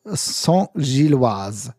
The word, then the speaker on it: Saint-Gilloise: Union, Jean, warte mal, Union, siehst du, genau, jetzt habe ich es falsch ja. gemacht. Union, Union Saint-Gilloise oder einfach, du kannst auch nur den Verein aussprechen, also äh, Saint-Gilles. Entweder Saint-Gilles, das geht auch, oder Union Saint-Gilloise.